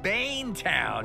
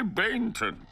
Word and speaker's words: Bainton: You got your hands full with Bane Town. – It's the locals, we say Bainton. The way you remember it is it rhymes with plankton.